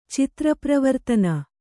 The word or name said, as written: ಚಿತ್ರಪ್ರವರ್ತನ